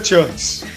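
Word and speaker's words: CHVRCHES: Não, a pronúncia é Churches. – É, é Churches.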